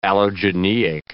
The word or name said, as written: allogeneic